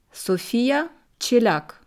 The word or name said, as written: Sofia Chelyak